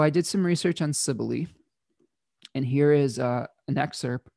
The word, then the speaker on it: Cybele: I've seen it pronounced a number of ways. I'm going to call it Cybele today.